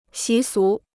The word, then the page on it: 习俗: xí sú